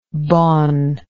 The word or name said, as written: bon